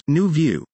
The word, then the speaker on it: Nuvu: Galili founded Nuvu, pronounced New View, Parenting in January 2023 because she believes parents shouldn't have to just try to figure it all out as we go along.